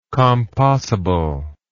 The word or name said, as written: compossibility